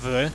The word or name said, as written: vy